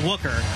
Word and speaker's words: Wucherer: Yeah, she has seven, Wooker does, hitting at 294. The last name, by the way, W-U-C-H-E-R-E-R, pronounced Wooker,